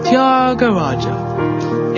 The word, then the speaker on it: Thyagaraja: I can't sing like Thyagaraja. – It's pronounced Thyagaraja.